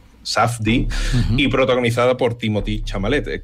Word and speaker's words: Timothée: Timothy, a veces francés, y es Timothée, o sea, que lo que tú quieras. – Es Timothy, se produce Timothy, creo yo.